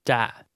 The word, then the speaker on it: จะ: จะจะผมจะผมจะทานจะ is pronounced with a low level tone.